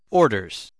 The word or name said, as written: orders